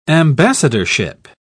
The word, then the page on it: ambassadorship: British English